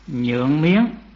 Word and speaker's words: Nhượng: Rồi sư cùng một vài bậc kỳ túc đến phản sông Nhượng Miếng, chữ Nhượng mà có khi cũng đọc là Tương đó, Tương Miếng.